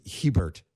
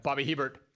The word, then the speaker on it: Hebert: Herschel Walker, Bobby Hebert, who on my audio recording, which got sent back, the guy pronounced it Hebert the entire time. – Bobby Hebert.